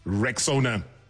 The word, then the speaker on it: Rexona: It is pronounced Rexona.